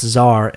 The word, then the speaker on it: czar: It's pronounced czar and is Russian for boss of all shit.